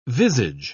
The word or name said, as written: visage